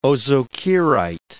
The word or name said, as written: ozokerite